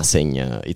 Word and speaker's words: Senj: Senja, it's spelled S-E-N-J. Senja.